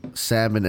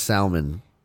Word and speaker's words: salmon: Oh, when people pronounce salmon as salmon... – Oh, throw the L.